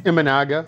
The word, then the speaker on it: Imanaga: Is that how, am I pronouncing his name right? – Imanaga. – Imanaga. I'm sorry. Imanaga. I should know that.